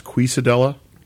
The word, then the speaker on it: quesadilla: 21st Amendment, shout out to them for the pre-beers and the awesome quesadilla. – I think that's pronounced quesadilla. That's Castilian Spanish.